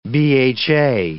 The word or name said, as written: BHA